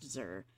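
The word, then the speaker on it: Xûr: I think it's pronounced Xur.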